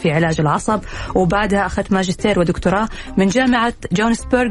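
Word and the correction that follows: Gothenburg: بالسويد، صح كذا الاسم انا نطقته صح؟ – جوثنبرغ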